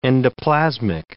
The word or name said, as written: endoplasmic